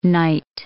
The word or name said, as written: knight